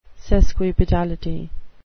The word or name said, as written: sesquipedality